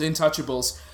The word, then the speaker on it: Intouchables: But the original is Intouchables. – No, Intouchable is how it's pronounced.